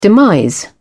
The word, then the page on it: demise: British English